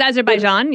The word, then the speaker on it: Azerbaijan: So we, for example, when we resettled a family of seven from Azerbaijan, I don't think I'm quite saying that right. – It's close, Azerbaijan, yep.